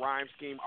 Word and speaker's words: Reime Schemes: Title, you know, definitely it's spelled, my name is spelled Reime Schemes, R-E-I-M-E-S-C-H-E-M-E-S, and that's the German way for rhyme, so if y'all want to know a little story behind that.